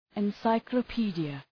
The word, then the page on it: encyclopaedia: ın,saıklə’pıdıə